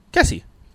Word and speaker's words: Kesy: So Jack Kessie, I think that's how you pronounce that. K-E-S-Y, Kessie.